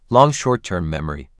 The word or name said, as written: long short-term memory